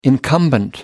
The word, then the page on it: incumbent: American English